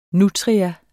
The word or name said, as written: nutria